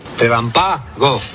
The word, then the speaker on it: relámpago: Relámpago. Relámpago es una palabra esdrújula. Y las esdrújulas se acentúan en la vocal de la antepenúltima sílaba.